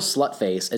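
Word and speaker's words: Sløtface: They use that, which has the same sound. So there's still Slutface.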